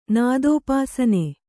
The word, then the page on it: ನಾದೋಪಾಸನೆ: kannada